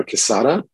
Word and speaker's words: Quezada: My name is Mario Quezada.